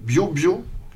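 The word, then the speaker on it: Biobío: Et dans Les Libérateurs, en fait, Neruda commence son chant en disant que c'est le bio-bio. Hein, Modesta, si tu m'écoutes, tu vois, je l'ai bien prononcé. Bio-bio. C'est pas bio-bio.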